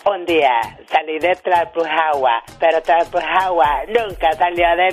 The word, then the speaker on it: Tlalpujagua: Un día salí de Tlalpujagua, Michoacán.